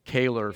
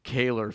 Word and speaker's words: Kaler: I told you my last name, it rhymes with failer. Kaler Failer. Kaler Failer.